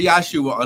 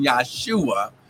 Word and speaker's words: Yahshua: Okay, when I say Yahshua, understand there's no J in the Hebrew language, so his name would not have been pronounced Jesus. It would have been Yahshua.